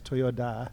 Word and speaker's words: Toyoda: How it's, I think it's Toyoda. – Right? Toyoda. It's with a D. Yeah. That's right.